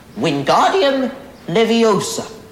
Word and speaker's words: Wingardium Leviosa: Wingardium Leviosa.